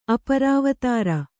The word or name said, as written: ಅಪರಾವತಾರ